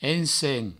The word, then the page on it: ensems: ensen; provençal